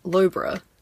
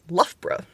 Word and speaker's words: Loughborough: I would pronounce that as Loughborough. – That's also what I said. That is apparently not correct. It is Loughborough. – Oh, okay. Yeah, I can see that. Like, cough.